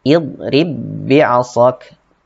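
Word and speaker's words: idrib bi'asak (اضرب بعصاك): Seperti tadi bacanya idrib bi'asak tidak dikolkolahkan. Idrib bi'asak tidak dibaca idrib idrib bi'asak tidak seperti itu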